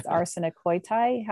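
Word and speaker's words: arsenikoitai (ἀρσενοκοῖται): Now I'm probably going to mispronounce it, but the way I pronounce it is arsenikoitai. How do you? – Yeah, that's right.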